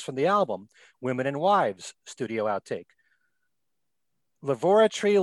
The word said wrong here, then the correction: Lavatory: Lil, I had to make sure I pronounced that because I keep saying laboratory. Lavoratory Lil, studio it's lavatory.